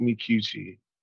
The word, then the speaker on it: Micucci: And then I'm gonna have to give a shout out to what's her name, it was episode 4, and her last name is Makuchi, I believe. I actually really like her. I think it's Martha. Is it Martha Makuchi? Oh, it's, here you go. Kate Micucci. And I probably butchered her last name because apparently I can't talk.